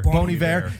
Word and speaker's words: Bon Iver: That song with, I kept saying his name wrong. Landlord gets mad. I'm like, Bon Iver. It's Bon Iver. – Bon Iver.